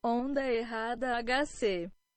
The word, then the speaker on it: Onda Errada: So wrong wave HC is what it translates to, or Onda Errada HC.